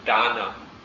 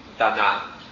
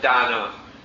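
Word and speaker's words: dāna: We usually hear the word dana, not dana, or not the word translated as treasure, but dana, D long A-N-A, although you don't always see the bar over the first A.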